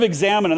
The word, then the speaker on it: examen: The prayer of examen, and that's spelled E X A M E N, it's Latin, is a prayer of reflection.